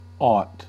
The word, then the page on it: aught: American English